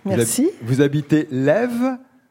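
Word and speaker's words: Laives: Ça s'écrit L-A-I-V-E-S. C'est ça. Lève.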